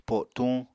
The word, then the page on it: po7tuñ: Apatani